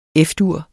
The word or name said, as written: F-dur